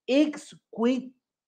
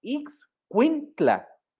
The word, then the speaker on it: Ixcuintla: Ella nació en un pueblito llamado Santiago, y a lo mejor pronuncio esto mal, pero discúlpenme, Santiago Ixcuic. Quis, no, Ixcuintla, creo que lo dije bien, en el estado de Nayarit, México.